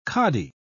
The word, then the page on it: kadi: British English